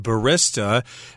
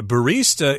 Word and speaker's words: barista: And some people pronounce this word barista, but uh, barista is the pronunciation that at least I have in my dictionary.